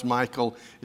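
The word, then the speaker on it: Michal: When Saul saw and realized that the Lord was with David, and we're jumping ahead in the story, and that Michael, and yes, it is pronounced Michael, it's a hard CH.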